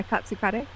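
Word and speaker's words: katsu kare: My favorite way to eat tonkatsu is as katsu kari. Is that how you pronounce it? – Yeah, katsu kari.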